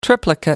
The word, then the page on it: triplicate: American English